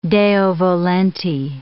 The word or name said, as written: Deo volente